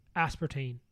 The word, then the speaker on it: aspartame: I've heard some weird comments about aspartame.